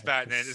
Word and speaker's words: Vatanen: Yeah, it's Vatanen.